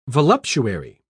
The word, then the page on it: voluptuary: British English